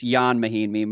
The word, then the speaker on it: Mahinmi: No, I'm still like, look, I, first of all, it's Jan Mahinmi, my bad, but I'm still, you're really on a pronunciation kick this episode.